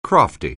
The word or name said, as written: crafty